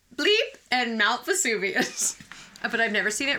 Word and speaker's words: Vesuvius: V-E-S-U-V-I-U-S.